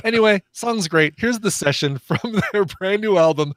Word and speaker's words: Seshen: The San Francisco Bay Area has spawned a band called The Session, S-E-S-H-E-N, Session. So don't spell it like you think you want to spell it. Spell it S-E-S-H-E-N.